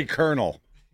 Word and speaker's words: colonel: How do you say colonel? – Colonel. – He was an honors English. – Yeah, this is honors English. – And he pronounced colonel wrong. – That gets you kicked out of the advanced placement classes. – But it doesn't look like colonel at all.